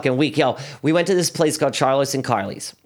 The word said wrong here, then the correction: Carlos and Charlie's: No, Carlos and Charlie's. Said it wrong again.